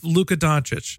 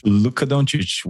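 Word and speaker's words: Luka Dončić: So home of Luka Doncic. – Luka Doncic, one and only, yeah. – Probably mispronouncing his name as well. My favorite. – Actually, it was very good.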